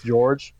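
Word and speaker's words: Gheorghe: George Mirasan. Come on. – George. It's George. – Yeah, that's everybody knows George Mirasan. – I would never assume that's pronounced George. I'm thinking like Georg.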